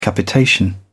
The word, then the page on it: capitation: British English